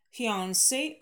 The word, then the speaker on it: fiancé: Произносим с ударением на второй слог оба слова fiancé.